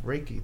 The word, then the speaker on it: Reiki: If you are here in Los Angeles and are interested in learning more about our guest Sharon's work in grief Reiki, did I say that right? – Yes, you did.